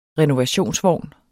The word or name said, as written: RENOVATIONSVOGNE